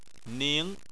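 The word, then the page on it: ning: tone 2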